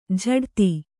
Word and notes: ಝಡ್ತಿ: jhaḍti; Kannada

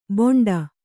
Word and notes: ಬೋಂಡ: Kannada

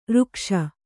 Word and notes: ಋಕ್ಷ: řkṣa

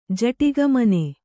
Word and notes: ಜಟಿಗಮನೆ: Kannada

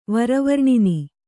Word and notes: ವರವರ್ಣಿನಿ: vara varṇini

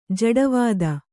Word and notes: ಜಡವಾದ: jaḍa vāda

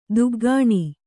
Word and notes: ದುಗ್ಗಾಣಿ: duggāṇi

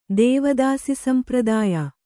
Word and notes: ದೇವದಾಸಿಸಂಪ್ರದಾಯ: Kannada

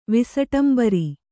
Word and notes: ವಿಸಟಂಬರಿ: visaṭabari

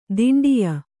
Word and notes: ದಿಂಡಿಯ: diṇḍiya

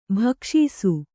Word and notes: ಭಿಕ್ಷಿಸು: bhikṣisu

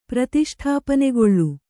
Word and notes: ಪ್ರತಿಷ್ಠಾಪನೆಗೊಳ್ಳು: pratiṣṭhāpanegoḷḷu

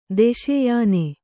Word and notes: ದೆಸೆಯಾನೆ: deseyāne

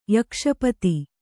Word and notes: ಯಕ್ಷಪತಿ: Kannada

